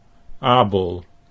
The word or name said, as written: Abel